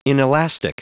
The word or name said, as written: inelastic